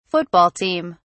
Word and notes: football team: British English

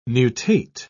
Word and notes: nutate: British English

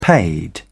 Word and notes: paid: British English

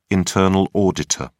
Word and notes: internal auditor: British English

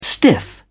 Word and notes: stiff: British English

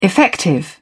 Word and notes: effective: British English

